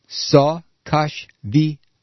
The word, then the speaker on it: Saakashvili: But if you listen here to this 37-second soundbite, which he read, he repeatedly mispronounces the Georgian leader's name. Now, this is somebody he's met many times. His name is Mikhail Saakashvili.